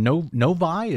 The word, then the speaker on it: Novi: Here's his remarks from Novi. Is that how you say it? Yeah, I think it is. – Novi, Michigan.